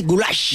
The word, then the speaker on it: goulash: Es el goulash es pronunciado así, goulash.